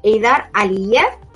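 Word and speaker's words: Heydar Aliyev: es la Fundación Eidar Aliyev, creo que lo he dicho bien. – Y, sí, sí, lo ha dicho muy bien.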